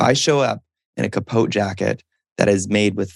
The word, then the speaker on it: capote: Capote.